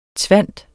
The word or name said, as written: tvinde